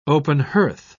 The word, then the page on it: open-hearth: British English